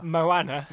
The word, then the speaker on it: Moana: The Disney movie Mona... Is it Mona or Mona? – Moana. – Jeez. I written Mo. I put M-O-A-N-A. – Yeah, that's how it's spelled.